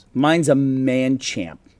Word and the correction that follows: Machamp: Machamp? – Machamp, thanks.